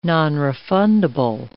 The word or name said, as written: nonrefundable